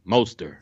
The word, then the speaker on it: Mostert: Yeah, it's like Most, M-O-S-T, Stir, Mostert.